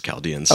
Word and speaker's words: Chaldeans: In my head, I pronounced the H. That's actually so good for me to hear. That way I don't pull another coal. – Well, maybe I'm wrong here, but I think it's Chaldeans.